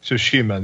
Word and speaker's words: Tsushima: Tsushima, there you go.